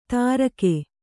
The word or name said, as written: ತರ್ಕೆ